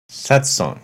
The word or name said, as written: Satsang